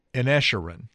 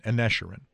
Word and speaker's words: Inisherin: The Banshees of Inisherin, and I have no idea if I'm pronouncing that right. – Yes. – The Banshees of Inisherin, that's it, Inisherin, Inisherin, took in $28 million at the box office.